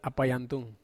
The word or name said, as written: apai antung